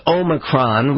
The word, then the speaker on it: Omicron: Now, here we have a name that is so new on the world stage that I don't think President Biden has quite gotten a handle on the pronunciation. But it is Omicron. We spent some time trying to figure out exactly what is correct. That is the 15th letter of the Greek alphabet.